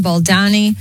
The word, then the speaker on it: Baldoni: Just weeks after settling her case, Blake is taking Justin Baldoni, I'm saying that right, back to court.